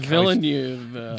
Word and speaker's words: Villeneuve: I butcher his name all the time, but like in newer guys, Dennis, the guy's Villeneuve, is that his name? Villeneuve.